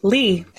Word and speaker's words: Lee: Lee, L E E very oh, specifically.